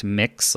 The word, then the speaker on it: Mx: It's MX and it's pronounced mix, like you're mixing a bowl mm-hmm.